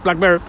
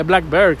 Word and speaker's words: BlackBerry: You don't know what is Blackberg y luego me decían, no, el tonto eres tú que no se pronuncia Blackberry, se pronuncia Blackberry, Blackberry.